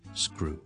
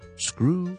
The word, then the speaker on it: screw: 好啦，前面加个 s 音，而嗰个 crew 咧，因为前面有个 s 音咧，我就通常就唔使喷气嘅，读成好似 grew 咁就得噶啦，grew grew grew，加个前面 s 音 screw。 screw screw screw 螺丝批我哋叫做 screwdriver，仲有啲咩系有啲咁嘅 sko 音咧？